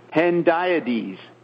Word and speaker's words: hendiadys: It's the hendiadys. – These are key points. – How do you say that word? – Hendiadys. – Hendiadys. I was saying it incorrectly.